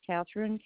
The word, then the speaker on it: Kathryn: Catherine, K-A-T-H-R-Y-N. Last name is Kraft, and it's spelled K-R-A-E as in Edward, F as in Frank, F again like Frank, T like Tom.